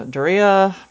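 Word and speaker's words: Daario: Even though Daria, that's how it's pronounced, I forgot, tries to talk her out of it.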